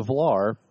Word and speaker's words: Velar: The Velar is 4.8 meters long.